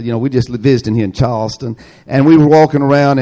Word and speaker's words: Charleston: I found out that the way that they know that you're not a local, if you pronounce it Charleston, the locals there call it Charleston. They leave out the R and the L. It's Charleston.